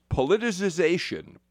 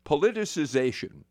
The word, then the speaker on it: politicization: I am particularly grateful to Kate Geek, who reminds me it's pronounced politicization. Politicization.